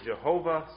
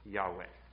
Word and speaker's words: Yahweh: So some would pronounce it Jehovah, some have pronounced it Yahweh or Yahweh. The best understanding of ancient Hebrew is it is probably pronounced Yahweh.